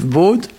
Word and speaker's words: bod: Et aujourd'hui, on va, pour Guerriou Hervue, la première partie, on va parler d'un mot court, BOD, B-O-D. Alors en fait, il y a deux mots différents qui s'écrivent BOD et qui se prononcent BOD.